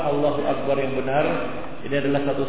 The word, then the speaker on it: Allahu Akbar: Allahu Akbar bukan hu tapi u Allahu Akbar begitu ya.